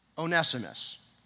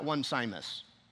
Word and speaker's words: Onesimus: Philemon had a slave, Onesimus. It's not one Simus. You have to put the right emphasis in the right syllable. It's Onesimus.